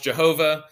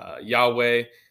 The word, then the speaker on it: Yahweh: God's name is Yahweh, sometimes pronounced Jehovah, but it's really more properly to be pronounced Yahweh.